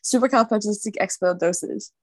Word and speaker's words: supercalifragilisticexpialidocious: Ruby, supercalifragilistic expialidocious. Is it supercalifragilisticexpialidocious, supercalifragilistic expialidocious? – We're really ending with a bang here because you are correct.